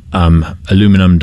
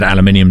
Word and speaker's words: Aluminum: I mean, there are a number of companies like Elon Musk's, like Amazon, like Aluminum Dynamics. – Yeah. – I nearly said Aluminium Dynamics. Forgive my pronunciation.